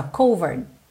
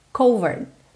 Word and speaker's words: covered: A mesma coisa com covered, porque você leria ali sem saber, se você não fosse seguidor, seguidora aqui do canal, você ia pensar covered, mas esse E final não é pronunciado. Se ele não é pronunciado, essa minha última sílaba fica um RD, ou seja, quase não aparece, ó, covered, covered.